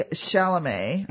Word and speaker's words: Chalamet: So, Austin starred with Timothy Chalamet. I think that's how you say his name. I yes. – think the T on the end is silent.